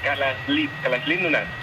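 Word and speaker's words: Kalaallit Nunaat: Nada, allí cuando juntan las dos L le ponen una S y una L. Kalalit Nunaat.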